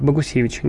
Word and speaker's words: Богусевич: А, на детском Евровидении этого года победила российская певица Полина э, Богусевич. Нет, я, по-моему, искажу фамилию. Да, все правильно. Полина, как-то верно заметил Богусевич. Дом ударения падает именно так.